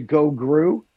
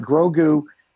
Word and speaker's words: Grogu: Well, or I keep wanting to say Gogru. you know? – Yeah. So, but putting the R in the first syllable is getting difficult for me. But Grogu um, getting captured, and then they go and, and, and save him, and, and maybe somebody else is in jeopardy.